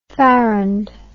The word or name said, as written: farrand